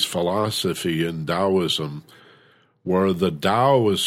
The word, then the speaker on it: Tao: And Taoism is no different in that regard, where the Tao, it's pronounced like D like David, O-W, Tao, but it's spelled T like Tom, A-O. Looks like Tao, pronounced Tao.